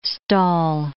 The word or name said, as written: stall